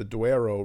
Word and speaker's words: Douro: If you go north, the Douro, how I believe you pronounce it in Portuguese, but the Douro River, which goes through Spain and Portugal, the north.